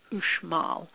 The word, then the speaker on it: Uxmal: It's a pyramid complex at Uxmal, Uxmal, pronounced Uxmal.